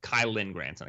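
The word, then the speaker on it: Kylen Granson: Didn't really love Kyle Granson as a prospect. Did not see him as a fourth-round prospect. Kylan Granson. I keep saying his name wrong. Kylan Granson. Didn't think Sean Davis was draftable out of Florida.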